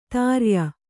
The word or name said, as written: ಧೌರೇಯ